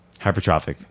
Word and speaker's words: Hypertrophic: All right, hypertrophic scars are uncommon and are characterized Hypertrophic.